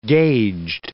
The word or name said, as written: gauged